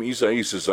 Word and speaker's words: Isaías: All right, very interesting name, Isaías. I understand that's how you pronounce Isaiah in Spanish and Portuguese.